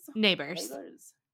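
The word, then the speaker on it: Nabors: Neighbors.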